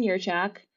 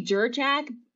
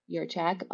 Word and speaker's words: Jurchak: Yeah, so TikTok is my name, Erin Dugan Yerchak. Uh, most people say Jerchak, but it, the the J is actually pronounced as if it were a y. Mm-hmm. – Um, so it's Erin Dugan Yerchak.